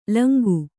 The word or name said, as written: ಲುಂಗ